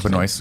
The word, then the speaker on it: Benoist: Benoit? How do you pronounce Benoist.